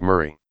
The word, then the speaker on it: Moray: In Gaelic, Moray is actually pronounced Murray.